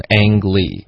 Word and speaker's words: Ang Lee: 外国人都读成 Ang Lee，